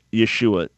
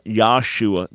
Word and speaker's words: Yeshua: But when we just say it in English, Yeshua, not Yahshua, there's no such name that ever existed as Yahshua any more than my name is Makel, okay? – Okay.